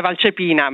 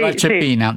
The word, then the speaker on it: Valcepina: Il cognome è pronunciato abbastanza bene, siamo da otto, l'accento è Valcepina, ma va benissimo così. – Ah, è Valcepina. – Ah, sarebbe sì, Valcepina, sì.